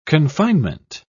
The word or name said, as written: confinement